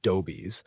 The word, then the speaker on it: adobes: 9/10 of the buildings are of adobes, or sun-dried brick, throughout the West, spelled and pronounced dobies, which material corresponds nearly with brick in the East, and where plastered and stuccoed makes an elegant and durable building.